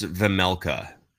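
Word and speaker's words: Vejmelka: I think it is pronounced Vemelka. – Vemelka.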